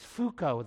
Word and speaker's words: Foucault: Probably the most modern, the most famous postmodernist is Michel, it's Foucault, the way you say his name, it's pronounced, it looks real different.